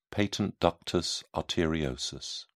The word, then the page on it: patent ductus arteriosus: British English